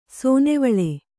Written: ಸೇನೆವಳ್ಳ

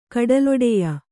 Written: ಕಡಲೊಡೆಯ

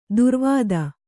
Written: ದುರ್ವಾದ

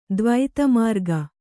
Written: ದ್ವೈತಮಾರ್ಗ

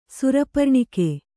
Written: ಸುರಪರ್ಣಿಕೆ

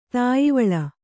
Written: ತಾಯಿವಳ್ಳ

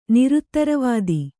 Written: ನಿರುತ್ತರವಾದಿ

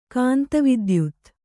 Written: ಕಾಂತವಿದ್ಯುತ್ತು

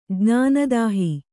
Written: ಜ್ಞಾನದಾಹಿ

